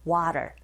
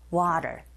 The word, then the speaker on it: water: 모음과 모음 사이에 t 사운드가 있으니 flap 현상에 의해서 water, water. 이렇게 발음하시면 됩니다.